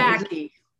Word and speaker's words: Psaki: Psaki. – Psaki? – The P is silent.